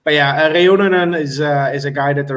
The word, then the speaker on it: Reunanen: Pronunciation is tricky with some European names, but his last name is pronounced Reunanen, so...